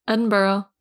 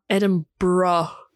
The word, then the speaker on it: Edinburgh: Edinburgh. – Edinburgh. You're adding too many syllables.